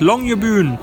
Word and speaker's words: Longyearbyen: Ja, das nördlichste Jazzfestival der Welt findet hier oben statt in Longyearbyen. So spricht man das Longyearbyen.